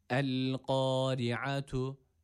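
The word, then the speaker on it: El-kari'atu (القارعة): Buradaki esreli olan ra ince okunması gerekiyor. Yani i sesini vermemiz gerekiyor buradaki ra'ya. Ra harfi esreli olduğu zaman ince okunur demiştik. Burada da i sesiyle okuyoruz. El-kari'atu. Evet, zaten şöyle çok sıkça okunan bir hata. El-kari'atu, el-kari'atu diye çok sıkça yapılan bir hata var.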